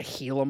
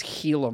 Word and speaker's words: Gila: A Gila monster? – You know it's actually pronounced a Gila monster? – Oh, is it really? – It's Gila monster.